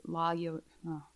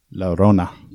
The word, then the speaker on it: Llorona: Well, one of them that I found really interesting, and it was more in the Hispanic community, is La Yo- oh. – Llorona.